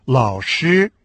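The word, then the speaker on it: лао-ши (老师): А учитель или преподаватель по-китайски лао-ши – третий и первый тон.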